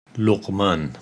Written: Luqman

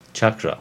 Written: Chakra

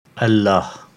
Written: Allah

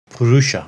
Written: Purusa

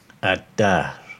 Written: Ad-Dahr

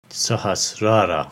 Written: Sahasrara

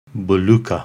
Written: Bhuloka

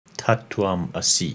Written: Tat Twam Asi

